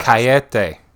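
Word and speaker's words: cajeta: Cayete. – Cayete? – Yeah. – Cayete. – Okay. Horchata, horchata cayete. cayete. – Yes. Um. The J is an H.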